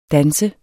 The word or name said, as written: DANSES